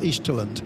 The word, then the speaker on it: Easterland: I thought it was East Talent from the East, but it's apparently pronounced Easterland. – Okay. – That's like indolent, almost.